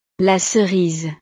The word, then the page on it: la cerise: frances